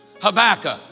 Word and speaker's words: Habakkuk: Habakkuk rhymes with tobacco.